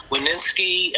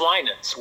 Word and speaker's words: Winans: They'd say, Wininski. I'm like, there's no S-K-I at the end of that. – Right. – They go, Weiner. There's no R in it. And a lot of Winans, but it's Winans with the I being long.